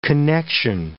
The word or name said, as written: connection